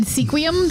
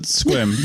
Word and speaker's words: Sequim: Just so everybody knows, it's spelled Sequim. It's obviously a Native American word. A lot of place names in Washington are. But it's pronounced Squim.